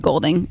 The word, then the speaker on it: Goulding: Isn't there a U in there? – There is, but apparently you don't pronounce it. – Yeah, it's just Goulding.